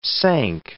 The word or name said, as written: sank